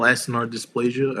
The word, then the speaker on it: acinar dysplasia: And that's when they said that she had congenital acinar dysplasia. I don't know if I'm pronouncing that. Yeah, that's right.